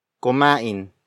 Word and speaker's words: kumain: Mabagal kumain si Chris.